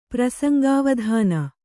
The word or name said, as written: ಪ್ರಸಂಗಾವಧಾನ